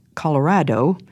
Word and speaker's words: Colorado: that Colorado, pronounced Colorado in Spanish, simply means red.